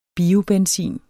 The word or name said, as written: biobenzin